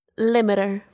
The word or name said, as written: Limiter